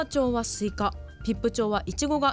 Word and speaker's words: 比布: 漢字で書くと比較の日に布と書いてピップと読む